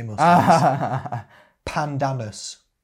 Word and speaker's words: Pandanus: Pandanus. – That's how it's spelled. Pan, P-A-N, and then D-A-N-U-S. Pandanus.